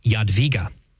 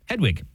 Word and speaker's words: Jadwiga: This is Saint, it's pronounced Jadwiga. But it looks like Jadwiga to me. – I know, exactly. Well, you can also... – Just to hurt your wife who's listening. – Yeah, you can also pronounce her name as Hedwig. That's the German version.